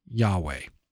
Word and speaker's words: Yahweh: And it's translated with those four letters, L-O-R-D, in small caps. But it's really the Hebrew letters that are probably pronounced Yahweh, God's covenant name.